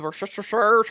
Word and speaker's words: Worcestershire: Worcestershire.